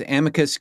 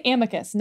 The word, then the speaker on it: amicus: amicus curiae. What is the official pronunciation of that? – I always say it. I never say the second word. And I say amicus, not amicus.